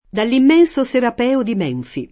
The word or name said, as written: Menfi